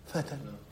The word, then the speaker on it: فتى: شوف إيش النطق فتن.